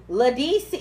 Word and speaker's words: Ledisi: Ledisi, how you say her name? L-E-D-I.